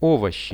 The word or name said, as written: ovoshi